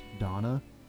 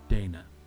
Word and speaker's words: Dana: Because she is effectively German, her name is pronounced Donna, not Dana.